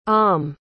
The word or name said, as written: alm